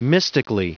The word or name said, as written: mystically